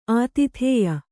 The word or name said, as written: ಆತಿಥೇಯಿ